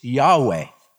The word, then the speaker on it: Yahweh: We don't really know definitively how to pronounce that name. The best we can come up with is YHWH is Yahweh.